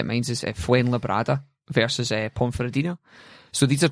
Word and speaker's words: Fuenlabrada: I've been putting an N in where there wasn't an N. So Fuenlabrada have won.